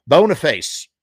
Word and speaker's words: Boniface: Boniface.